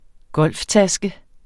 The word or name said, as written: golftaske